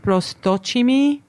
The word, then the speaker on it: Prosdocimi: It's actually pronounced Prosdocimi. Mm-hmm. But as, you know, when people move and they assimilate, the pronunciation sometimes, or even the spelling of last names can change.